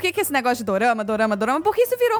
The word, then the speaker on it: dorama: Na verdade, o dorama ele é a pronúncia japonesa de drama.